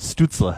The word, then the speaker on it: Stützle: Stützle.